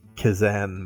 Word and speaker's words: Kazan: The Kazistan Mystic? Is that how it's pronounced? – The Kazan Mystic.